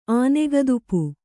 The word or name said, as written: ಆನೆಗದುಪು